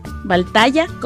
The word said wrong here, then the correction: Valhalla: ¿cómo es? Valhaya. ¿Sí? Ajá, Valhalla. Ok, muy bien. Valhalla, como, como los vikingos.